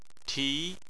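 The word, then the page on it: ti: tone 2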